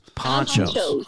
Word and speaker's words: Pancho's: Am I saying this right? Pancho's Mexican Buffet? – You're not. Pancho's. Come on. – Wow, Jesus. Sorry. God. How do you say it? – Ponchos. – Oh. – Like Lefty and Poncho. – Like Pancho Villa.